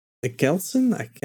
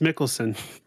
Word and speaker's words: Mikkelsen: I think it's pronounced Mikkelsen. – Yeah, that's probably it. – Yep, Mads Mikkelsen. That's at least that's how I've heard it.